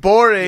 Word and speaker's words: Gborie: Ooh, David Gborie.